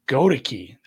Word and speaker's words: Goedeke: Sorry, Gadeek? Go ahead. Well, how do you say the guy's name? The one tackle was limited. – Luke Goedeke? – Goedeke. There we go. I knew I didn't have it right.